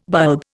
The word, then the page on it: bulb: Indian